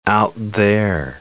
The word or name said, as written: out-there